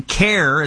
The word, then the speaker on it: CAIR: CARE is how people pronounce it, C-A-I-R.